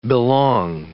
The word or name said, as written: belong